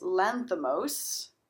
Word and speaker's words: Lanthimos: Yes and it's directed by Yorgos Lanthimos, which is probably the only time I will say that correctly.